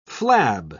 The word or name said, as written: flab